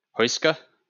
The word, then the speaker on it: Huesca: Huesca? – Nailed it.